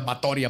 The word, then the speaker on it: Báthory: La condesa Elizabeth Báthory de Exed, porque leí, se pronuncia Báthory aparentemente, nació en Nibrator, en Hungría, en lo que ahora se conoce como Eslovaquia y Rumania, el 7 de agosto de 1560.